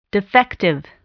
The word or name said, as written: defective